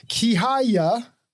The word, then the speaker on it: Kihaya: So Miss Lala, and I'm going to try this, Kihaya, welcome to your day off. Did I get it right? – You definitely got it right. And you actually pronounce it so well. You sound like a Romanian.